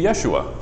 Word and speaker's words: Yeshua: We say Jesus. That's not how you pronounce it. It's supposed to be Yeshua.